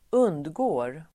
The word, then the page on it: undgick: [²'un:djik:]